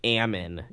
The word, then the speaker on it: almond: You also say almon instead of almond. – That's how it's pronounced.